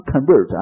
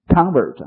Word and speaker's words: convert: 听到的是词组，那么选项用的单个的词叫 convert，但是这个词呢它的有动词的意思呢，这个人是动词是吧，动词读成 convert 啊，名词叫重音在前面叫 convert 啊。